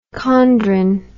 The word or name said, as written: chondrin